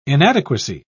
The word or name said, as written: inadequacy